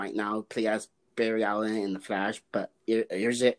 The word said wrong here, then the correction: Ezra: Irza, I think that's how you say his name, right? Irza, Irza? – Ezra, yeah, Ezra Miller. Ezra yeah. Miller.